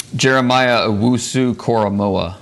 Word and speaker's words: Jeremiah Owusu-Koramoah: Jeremiah Owusu-Koramoah.